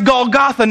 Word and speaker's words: Golgotha: In Hebrew, it's pronounced Golgotha.